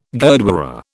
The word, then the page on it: gurdwara: British English